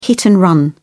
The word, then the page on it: hit-and-run: British English